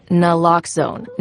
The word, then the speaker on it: Naloxone: For the record, it's Naloxone. Nuh, like, is Naloxone addictive? Nuh. Lox, like Naloxone keeps humans safe, like locks keep bikes safe. And own, like Naloxone can save lives in our very own communities.